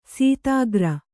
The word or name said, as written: ಸತ್ಕಾರ